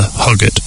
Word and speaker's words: Hoggett: So what 95 movie included the characters named Narrator, and Farmer Arthur Hoggett? Which could also be pronounced Hodget, so forgive me.